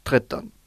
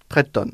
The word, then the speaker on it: tretton: Et pour finir, le chiffre 13, le chiffre magique, Tretton. – Treta. – Tretton.